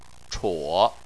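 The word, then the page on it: chuo3: tone 3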